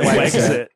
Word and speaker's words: Wexit: we don't want to pronounce it. We exit. That's too much. We'll pronounce it Wexit.